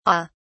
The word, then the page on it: au: British English